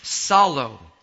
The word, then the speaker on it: psallo: It is salo. And that's spelled P-S-A-L-L-O.